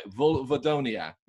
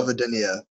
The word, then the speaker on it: Vulvodynia: I've been trying to say this correctly, so correct me if I've got it right. Vulvodynia, yeah? – No. Vulvodynia, but close enough.